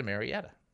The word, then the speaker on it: Murrieta: 1853, the famous California bandit Joaquin Murrieta, also known as Robin Hood of El Dorado, is killed in 1853. Now, what's amazing is he lived in Temecula, which is right next to Murrieta.